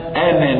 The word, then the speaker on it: أنا: أنا نذير، لفظ أنا في القرآن بأكمله، لفظ أنا أنا ألف أنا لا تنطق في الوسط، لكنك إذا وقفت عليها نطقت الألف أصبح مادة طبيعية، فتقول إنني أنا إنني أنا عند الوقف، إذا وصلت إنني أنا الله لا، وهكذا وهكذا.